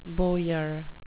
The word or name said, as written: bowyer